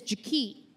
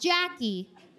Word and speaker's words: Jaki: Her name is Jaki. Not Jackie. Jaki. It's spelled J-A-KEE.